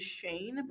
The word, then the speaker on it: Schoen: It's also like my last name is Shane, but it's spelled S-C-H-O-E-N.